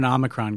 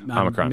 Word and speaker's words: Omicron: Omicron, Omicron, what are we going with? – I'm an Omicron guy. – Okay, Omicron.